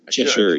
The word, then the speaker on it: Cheshire: Cheshire, you got it right.